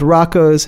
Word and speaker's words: Roko: Is it Rocco or Rocco? – I don't know, I've been saying Rocco. – But it's spelled R-O-K-O, correct? – Yeah.